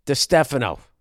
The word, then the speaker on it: DiStefano: Chris DiStefano. There, I finally said it right.